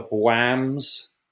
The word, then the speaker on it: BWAAM: what we call the Bwams. Is that right? Have I got that right? Bwam. – I've never heard that term before. What does that mean? – It's spelled B-W-A-A-M, as in bwam.